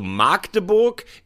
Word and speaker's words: Magdeburg: Du darfst nur das A nicht lang sprechen. Das ist ein Frevel. Also Magdeburg ist falsch. Magdeburg oder Magdeburg.